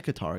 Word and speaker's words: Qatar: Did I say Qatar again? Wait, that's how you pronounce it. – That's right.